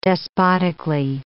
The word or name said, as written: despotically